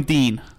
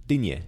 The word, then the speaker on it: Digne: I'm going Dean. – No way. – Well, what is it then? – Digne, surely. – Digne. – That's it.